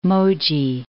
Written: Moji

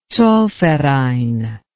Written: zollverein

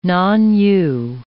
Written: non-U

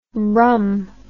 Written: brum

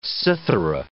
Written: cithara

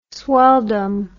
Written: swelldom

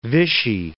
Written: Vichy

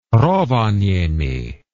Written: Rovaniemi

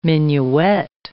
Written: minuet

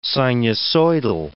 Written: sinusoidal

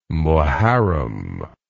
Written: Moharram

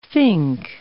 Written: fink

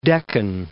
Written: Deccan

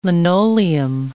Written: linoleum

